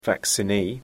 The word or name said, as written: vaccinee